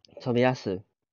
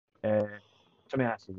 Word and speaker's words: Tomiyasu: Tomiyasu. – Tomiyasu, yes.